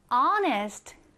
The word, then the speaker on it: honest: 이번에는 honest. honest라고 하는 단어가 나와 있는데요. 얘를 읽을 때는 honest라고 읽지 않아요. 맨 앞에 있는 이 H가 소리 나지 않는 글자라서 마치 H가 없는 것처럼 honest라고 읽어주시면 됩니다. 자, honest, 오른쪽에 한번 써보겠습니다.